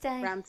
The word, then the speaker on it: Rammstein: how do you say that word? – Ramstein.